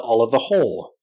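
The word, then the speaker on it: Oliver Hoare: Charles was seeing Camilla again, and Diana was seeing other people, including the art dealer Oliver Hall. Yes, that's how it's pronounced.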